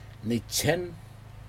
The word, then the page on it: nitjen: Aneityum